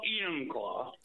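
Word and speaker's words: Enumclaw: Emmonclaw, Washington. Am I saying that right? – Well, Emmonclaw.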